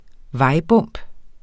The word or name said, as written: vejbump